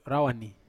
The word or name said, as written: rawani